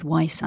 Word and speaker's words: Weiss: So really it's like German for white, so it's Weiss, but I always feel a little bit self-conscious saying that, so I tend to stick with Weiss, actually.